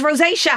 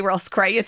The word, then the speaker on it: rosacea: And they said, it's rosacea. And it's like, oh yeah, see, I read things, I read it phonetically. I would need it spelt like Rosacea.